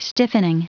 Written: stiffening

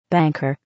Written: banker

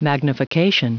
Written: magnification

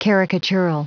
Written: caricatural

